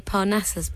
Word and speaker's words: Parnassus: You actually starred in the Imaginarium of Dr. Parnassus. Have I said that right? – I say Parnassus, but I think it's open.